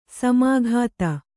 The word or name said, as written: ಸಮಾಗತ